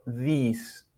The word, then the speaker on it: this: Notarán que la I no la pronunciamos como una I, no digo this, this, sino this, this. Can you repeat with me? This. La I en este caso suena como entre la E y la I del español. This. Perfect.